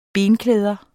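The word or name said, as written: BENKLÆDER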